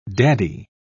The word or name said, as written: daddy